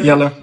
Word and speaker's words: Jelle: Yeah, Jelle, that's perfect.